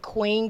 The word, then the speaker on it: Qween: My name under there is Shantae Queen, Q-W-E-E-N, Modena. And then Impact Model of the Year is spelled Q-W-E-E-N.